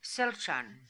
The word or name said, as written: Selchan